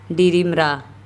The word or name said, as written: Dirimra